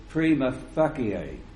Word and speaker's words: prima facie: And if it's classical Latin, it should be prima facie. But you don't want that, do you? – No. – Is that? – We spoke about that before. – Is that actually how you say it? – I was a Latin teacher, yes.